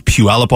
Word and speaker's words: Puyallup: You know I'm from the Northwest because I was able to pronounce Puyallup, Washington.